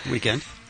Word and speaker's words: The Weeknd: Meanwhile, the rest of the Hadid sister update, Bella, as being mentioned, is dating The Weeknd. – Weeknd. – Look, I'm looking at his name right here. – I know how it's spelled. I'm just saying that's how it's said.